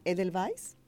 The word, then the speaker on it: Edelweiss: Díganme si lo pronuncio bien, ¿eh? Edelweiss. Así es.